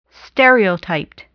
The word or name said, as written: stereotyped